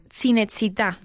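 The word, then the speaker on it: Cinecittà: En vuestro último programa habéis hablado de cinecita, pero su pronunciación no fue muy afortunada la, la pronunciación adecuada sería Cinechita.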